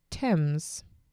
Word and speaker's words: Thames: So Corky is correcting me that it's pronounced Thames.